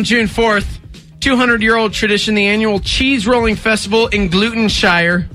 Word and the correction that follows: Gloucestershire: Gloucestershire. – No, it's called Gloucestershire.